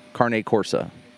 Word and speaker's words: Cane Corso: Well, it's spelled C-A-N-E, and they call it Carne. Carne Corso.